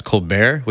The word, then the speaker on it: ColBERT: No, I'm not totally sure, but according to their website, it's supposed to be pronounced ColBERT, which is the name of some comedian on TV in the US.